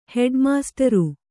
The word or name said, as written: ಹೆಡ್ಮಾಸ್ಟ್ರು